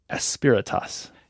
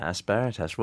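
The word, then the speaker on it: Asperitas: Tell me about the cloud Aspiratus. – Yeah, Aspiratus. Well pronounced.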